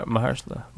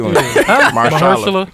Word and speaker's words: Mahershala: Marshalla? How you say his name? – Ali. Marshalla. – Marshalla Ali? That's right. – Mahershala. – Mahershala. – You made me say it like you. Mahershala.